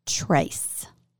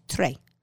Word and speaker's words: Tres: Ah, so it is called Cadillac and Donna Trace. T-R-E-S. Trey. Or is it Trey or Trace? – Tell me. – Trace would be T-R-A-C-E, so it's Trey.